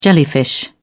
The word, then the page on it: jellyfish: British English